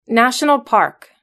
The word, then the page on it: national park: British English